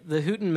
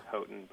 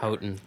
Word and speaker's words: Houghton: Now, for the Houghton Mifflin, did I pronounce it right? I can't remember. – It's Houghton, but whatever. – Houghton. There we go.